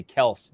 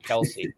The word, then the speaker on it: Kelce: It's actually Kels, not Kelsey.